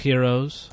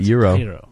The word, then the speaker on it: gyros: But they're also doing things like gyros. I've never been able to get a proper pronunciation, and I refuse to say gyro or hero. – What about euro? – Euro.